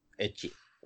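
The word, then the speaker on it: ecchi: Y la, pronunciación es así, ¿no? X, aunque se escriba x. – Echi.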